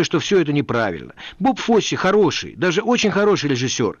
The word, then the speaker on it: Фосси: Во-вторых, постановщик Боб Фосси. В России его фамилию произносили ошибочно Фосс, полагая, что последнее «и» не читается.